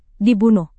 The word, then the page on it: dibunuh: Indonesian